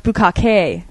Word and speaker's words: bukkake: And he's like, actually, it's pronounced Bukkake.